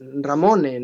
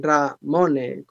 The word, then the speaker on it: Ramone: Sí, mi nombre como perfil de nombre comercial es Ramone, ¿no? Ramone con...